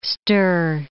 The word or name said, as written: stir